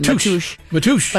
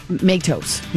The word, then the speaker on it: Matos: So I'm getting a lot of this on the texting line, and they're saying out of respect, it's the Portuguese pronunciation. – Matush. – But I think a lot of people are saying Matos.